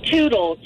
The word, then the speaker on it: Tootle: Tootle, T-O-O-T-L-E.